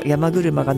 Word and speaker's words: 山車: この亀崎潮干祭りに出てくる山車、あ、出汁と書いて山車って読むんですね、 1台ずつ海際を引かれ 5 台の山車が並び、海際に並んだ後は芝生広場に引き上げて下段、下の段のからくりから披露した後、上段のからくりが披露され、その後に 1 台ずつ神社前に 5 台の山車が並び、